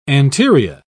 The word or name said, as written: anterior